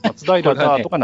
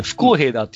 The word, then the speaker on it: 不公平だー: 松平だーとか懐かしいです。 – これ難しいんですけど、これ、ね、不公平だって読むんですこれ。